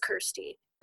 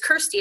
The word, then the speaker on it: Kirsty: Like, and I'm like, no, it's just Kirsty. Like, so I'm like, it's Kirsty. It rhymes with thirsty.